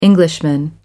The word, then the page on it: Englishman: American English